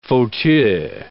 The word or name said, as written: fauteuils